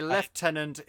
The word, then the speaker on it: Lieutenant: You know, Lieutenant is the American way, Lieutenant is the English way, even though there is no F in the word.